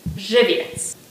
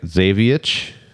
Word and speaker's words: Żywiec: Żywiec. Name for the town in south central Poland is pronounced Żywiec. – Żywiec? – All right, so you got that right. Żywiec.